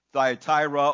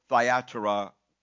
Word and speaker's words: Thyatira: It was a church of indiscriminate tolerance the third warning was the church of Thyatira or Thyatira, several ways to pronounce that, who was compromising with sin within the church.